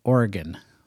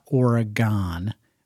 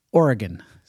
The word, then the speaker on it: Oregon: You got to pronounce it Oregon. That's what the locals say. Not Oregon, Oregon.